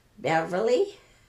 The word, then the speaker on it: Beverly: Beverly, B-E-V-E-R-L-Y, Dubaz, D-U-B-A-Z, Romeo, R-O-M-E-O.